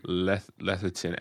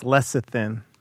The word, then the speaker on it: lecithin: Lecithin. L-E-T. I don't know. H. – I think it's lecithin.